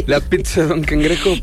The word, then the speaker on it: pizza: Como dato adicional, para que sepan, las palabras que llevan doble Z, que vienen de origen italiano, uh-huh. Se pronuncia como TZ o como TS. – Mira. – Pizza.